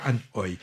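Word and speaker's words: Ann Hui: Et ça s'écrit Anhui. On avait pris que ça se disait Anne Oi.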